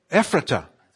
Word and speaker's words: Ephrata: Anybody know how to say it? Epaphrata, I believe. Is that right? Come on. Somebody help me. Epaphrata. Yeah? What? Ephrata. Okay.